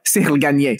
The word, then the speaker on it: Cyril Gane: Cyril Gane.